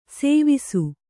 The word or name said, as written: ಸೇವಿಸು